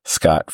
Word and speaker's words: Scot: In all these spots, you can also find my other little podcast venture, Scot Forgot the 80s.